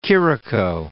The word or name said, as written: Chirico, de